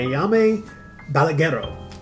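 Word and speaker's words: Jaume Balagueró: Sleep Tight from 2011, directed by Yame Balaguero.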